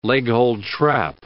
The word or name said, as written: leghold trap